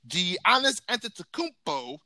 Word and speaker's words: Giannis Antetokounmpo: Giannis Antetokounmpo.